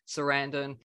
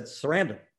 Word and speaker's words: Sarandon: The original name, when my father came to this country, was Sarandonidis. – Sarandonidis. – So the missing syllable was the emphasized syllable. And so when they shortened it, I'm assuming they did it maybe at Ellis Island or someplace. So when my father said it, he emphasized every syllable.